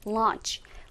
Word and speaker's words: launch: 그래서 우리가 론칭이라고 하지만 영어 발음으로는 launch. launch라고 한다라는 거 기억하면 좋겠고요. 자, 두 번째 단어, 탈 것이라는 의미 혹은 수단이라는 의미를 가지고 있는 단어는 강세가 앞에 있기 때문에 vehicle이라고 발음하시면 돼요.